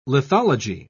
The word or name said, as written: lithology